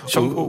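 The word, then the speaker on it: Zheng He: il paraît que ça se prononce Chang Ho, oui, effectivement.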